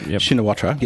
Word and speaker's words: Shinawatra: Well, according to the previous Prime Minister before she was outed, Yingluck Shinawatra, mm-hmm. I think I pronounced Shinawat? It. What? Yep. – Shinawatra, yes.